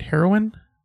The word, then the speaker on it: heroine: And, like, did I say that right? Heroine? – Yeah. – Heroine? It sounds like the drug. – It is. It's, yes, that's how it's the same thing, isn't it? – I mean, they're not the same thing, but it's pronounced the same.